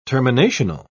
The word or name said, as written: terminational